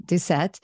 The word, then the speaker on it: Doucette: She and her husband, Peter Doucette, am I saying that right? – Doucette, an internationally certified mountain guide.